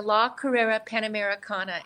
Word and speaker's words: La Carrera Panamericana: And I think, and I hope I don't mispronounce this, but is it La Carriera Panamericana? How do you say that? I know I've butchered it. – It's okay. So it's a Mexican race starting in 1950. It's the La Carrera Panamericana, and it's a 7-day, 2,000-mile race.